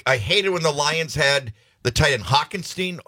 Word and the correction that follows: Hockenson: How do you pronounce his last name? – Hockenson. – Hockenson.